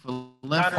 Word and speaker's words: Falefa: Falefa.